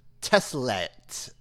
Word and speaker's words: Tessellate: Yeah, I'm looking up right now. The pronunciation is Teslet.